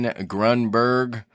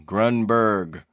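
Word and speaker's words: Grunburg: My name is Martin Grunberg. B-U-R-G, Grunberg.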